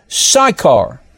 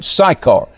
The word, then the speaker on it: Sychar: Then came he to a city of Samaria, which is called Sychar. That's how you pronounce this town, this village, praise God, Sychar, near to the parcel of ground that Jacob gave to his son Joseph.